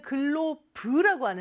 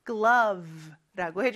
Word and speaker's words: glove: 근데 우리는 글로브라고 하는데, 저 V 발음 주의해서 glove라고 해주시면 돼요.